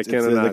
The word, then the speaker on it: cannonade: Yeah, cannonade. – It's the continuous firing of cannon. – Is that not what you were saying last time? – I was saying cannod. – He's leaving out a syllable.